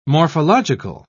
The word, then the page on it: morphological: British English